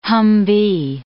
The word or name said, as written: Humvee